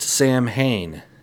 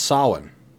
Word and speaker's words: Samhain: It's not pronounced Samhain. Best as I can understand, it's pronounced Samhain.